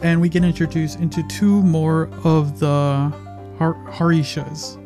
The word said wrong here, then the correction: Hashira: No, that's not how you pronounce it. I always forget how do you pronounce these guys. Hashira, Hashiras.